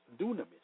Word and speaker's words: dunamis: The Greek word for miracle is dunamis, pronounced dunamis. The